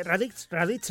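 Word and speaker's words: Raditz: Radix hasta Radix era la forma de pronunciarlo, hasta la época de lo del monstruo Buu y la destrucción de la humanidad, la salvación de la humanidad, todo ese asunto.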